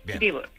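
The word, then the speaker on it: Dieburg: ¿Cómo ha sido tu vida para terminar en Dibur? ¿Lo digo bien? ¿Dibur, Alemania? – Sí, vale. Lo hice muy bien, Dibur.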